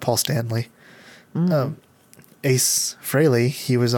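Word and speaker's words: Frehley: Ace Fraley from the band Kiss. – Uh-huh. – It's pronounced Fraley, but everybody says Fraley.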